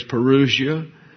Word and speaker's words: parousia: The English pronunciation in the American Oxford Dictionary is parousia.